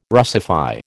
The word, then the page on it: Russify: American English